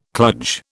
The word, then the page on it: kludge: British English